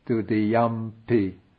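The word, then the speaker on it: dutiyampi: But when the yang is followed by p, like when we say dudiyampi budang saranang gacchami, that ung of dudiyang changes to an m, dudiyampi.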